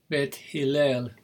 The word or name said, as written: bet Hillel